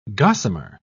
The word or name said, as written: gossamer